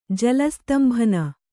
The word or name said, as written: ಜಲಸ್ತ್ತಂಭನ